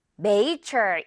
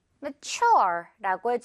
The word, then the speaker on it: mature: 자, 이 단어는 많은 친구들이 Major 이렇게 읽는 것 같더라고요. 자, 그런데 앞부분 여기 깃털 발음이에요. 그럼 어떻게 발음해요? May 이렇게 강하게 하면 절대 안 되고 뭐 거의 들릴 듯말듯뭐 이렇게 발음이 되겠죠? 그리고 뒷부분에 강하게 Mature라고 해주면 돼요.